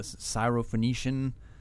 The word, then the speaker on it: Syrophoenician: Well, let's talk about the first one, the Syrophoenician, am I saying that right? – Yeah.